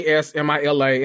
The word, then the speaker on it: Jasmila: Jasmila, and then the last name is Zbanic.